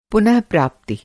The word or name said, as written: पुनःप्राप्ति